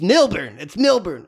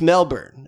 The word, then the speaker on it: Melbourne: I one time got lectured about it. It's Milburn. No, it's to Americans, it's Melbourne. There's just some things that are just culturally, it's where you grew up, is how you say them. – And the thing is, when I say Laura, like L-O-R-E, lore.